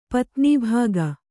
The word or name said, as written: ಪತ್ನೀಭಾಗ